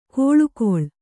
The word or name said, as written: ಕೋಳುಗೊಳ್